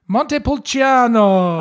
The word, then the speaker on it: Montepulciano: Montepulciano.